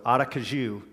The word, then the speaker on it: Aracaju: And you guys have probably heard that as Aracaju or Aracaju or all the different ways we people try to pronounce it when we're praying for you. But we do pray for, for you guys um, on a regular basis. So Aracaju is how you say that. Yes. And now we'll know.